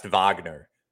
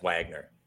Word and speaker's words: Wagner: Today it was mispronounced Wagner as Wagner. – I tried to say Wagner, and I was like, I'm just going with Wagner.